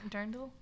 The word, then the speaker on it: dirndl: Dur- dirndl? Dirndls? – I don't know. I think I'm going to get dirndl one. Dirndl's the correct pronunciation, I believe.